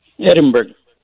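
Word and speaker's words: Edinburg: Edinburgh.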